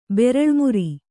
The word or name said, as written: ಬೆರಳ್ಮುರಿ